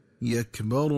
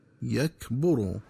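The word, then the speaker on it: يَكْبَرُوا: وكذلك ينبغي التنبه للنطق الصحيح لكلمة يكبر، فتقرأ بفتح الباء هكذا يكبر، ومن الخطأ قراءتها بضم الباء هكذا يكبر.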